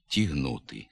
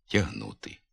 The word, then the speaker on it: тягнути: У ненаголошеній позиції літературний звук А – у покутських, гуцульських, надністрянських говорах, як правило, заступається звуком «і» – «челідь», «десять», «тігнути». Така вимова в літературній мові не допускається. Треба вимовляти «челядь», «десять», «тягнути». Відповідно до літературного звука «а», у говорах північного наріччя, на місці давнього «е» носового, у ненаголошеній позиції відомий звук «е».